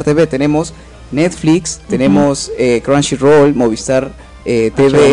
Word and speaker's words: Crunchyroll: Vayan a verlo a Chunchirol. – Crunchyroll. Exacto, Crunchyroll.